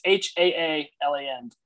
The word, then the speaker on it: Haaland: I think it's Haaland. – Dutch? – He's Norwegian with two A's. Haaland.